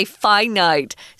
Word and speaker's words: finite: And it's weird that we pronounce these differently, Tom. Mm. You hmm. know, infinite infinite but why do we say finite? Sorry, that's how weird English is.